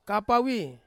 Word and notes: kapawi: Ansus

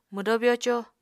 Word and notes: mwdó byoco: Apatani

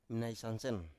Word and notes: mnaisansen: Biak

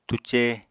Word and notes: túcé: apatani

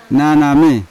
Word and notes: naname: Yawa

